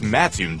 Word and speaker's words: Mattoon: It's Mattoon, dummy.